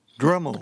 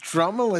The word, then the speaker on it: Dremel: The word is actually Dremel. – Oh, my goodness. I can't believe it. I mispronounced Dremel as Dremel.